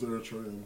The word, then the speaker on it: Eritrean: It's an R sound. – Eritrean. Like Aaron. Like, not air. I think Eritrean.